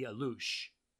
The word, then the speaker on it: Alux: I've got one group of little people called the Alush, spelled A-L-U-X, but pronounced Alush.